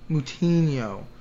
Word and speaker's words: Moutinho: How do you say his last name? – Chris Moutinho. – Moutinho. Yeah.